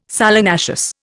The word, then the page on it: solanaceous: Indian